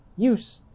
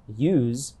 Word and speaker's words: use: The difference between use and use is one example of how speakers of American English sometimes pronounce nouns and verbs differently, even if they are spelled the same.